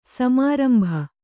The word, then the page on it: ಸಮಾರಂಭ: samārambha